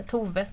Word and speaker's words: Thoufve: Exakt, det är en speciell stavning, men Tove ska det uttalas, ja. – Varför stavas det T-H-O-U-F-V-E?